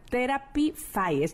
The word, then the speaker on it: Therapify: que nos platicaras precisamente sobre Therapy Therapify. Lo dije bien, ¿verdad? – Muy bien.